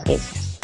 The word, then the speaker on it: geisha: Bueno, pues como iba diciendo, vamos a empezar con las geishas. La geisha, es como realmente se pronuncia,